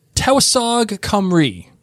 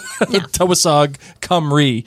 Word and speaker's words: Tywysog Cymru: Yeah, this is the title of this episode 306, is Tewasog Cymru.